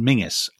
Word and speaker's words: Menzies: And his name, interestingly, so it's obviously a Scottish surname, which Scots will tell you is pronounced Mingus.